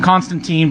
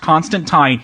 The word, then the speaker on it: Constantine: Well, first it's Constantine. I know they call him Constantine, but I want to get that out of the way or else some fan will correct us. It's actually pronounced Constantine.